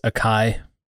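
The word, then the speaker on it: açaí: I don't know if it is pronounced that way still because they did not put the apostrophe acento marks in there. So oh. – That might change the pronunciation, but it's delicious. – Otherwise, it's acai, right? – Yeah.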